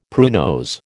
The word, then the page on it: pruinose: American English